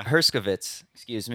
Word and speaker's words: Herskovitz: We have Jacob, last name, pronunciation, sorry if I botched, Herskovitz? – Herskovitz, yeah. – Herskovitz, excuse me.